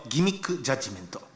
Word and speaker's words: 欺瞞裁判: さて、ここからは先週からスタートしたこちらの新コーナー、ザ・ナンバーロジック、数字のギミックジャッジメント。欺瞞裁判と書いてギミックジャッジメントって読ませるやつがこの世の中にいるんですよ、うん、もうね、はい、ありがたいじゃないですかこんなことを。